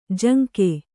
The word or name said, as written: ಜಂಗಿ